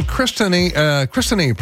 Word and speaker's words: Kristeny: And Kristeny, pronounced like Tiffany.